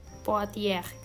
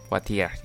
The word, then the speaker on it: Poitiers: Poitiers, itu nggak sih namanya beneran bacanya? – Poitiers. – Poitiers, ya itu ya.